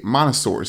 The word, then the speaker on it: Montessori: is in a Montessori school.